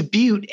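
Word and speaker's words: Beute: Sure, it's Butte, as in Butte, Montana, but spelled differently. It's B-E-U-T-E for folks that want to maybe connect on LinkedIn, Ethan Butte. And again, it's pronounced like the geological phenomenon.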